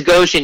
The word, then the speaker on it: Goshen: Am I pronouncing that right? Is it Goshen? – It is Goshen, yeah, which is actually smack dab where the Infinitus Trail race was.